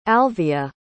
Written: alvia

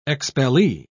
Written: expellee

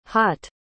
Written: haut

